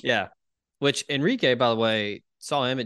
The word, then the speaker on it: Enrique: You mispronounced his name, as Houston Nutt would say, Enrique.